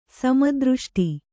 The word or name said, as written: ಸಮದೃಷ್ಟಿ